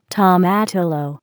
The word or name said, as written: tomatillo